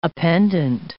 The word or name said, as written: appendant